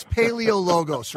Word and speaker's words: Paleologos: I said your name wrong, incorrectly. Tell me how do you say it correctly. I'm sorry. – No, I botched you. It's Paleologos, right? – There you go. – There we go. I left out a syllable.